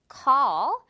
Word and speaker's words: call: call 역시 ALL 들어가 있어요. 그래서 call 아니고 call 이렇게 발음됩니다.